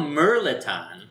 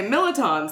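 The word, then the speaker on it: mirliton: I thought they were called Merliton. – Well, that's how you say it, because you're not from here. But nobody told me. – But good Southern people say Milliton stuffing.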